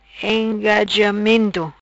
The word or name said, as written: Engajamento